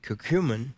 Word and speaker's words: curcumin: Now, we do have the offer on the curcumin.